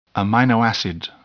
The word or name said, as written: amino acid